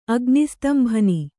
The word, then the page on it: ಅಗ್ನಿಸ್ತಂಭನ: Kannada